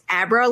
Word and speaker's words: Abra: It's like, you know, in Dr. Sleep, how the young girl's name is Abra, like, as in Abracadabra. Yeah. I went to school with someone who spelled it the same way, but she said Abra.